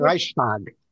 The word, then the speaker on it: Reichstag: I pronounce it Reichstag, but it's okay.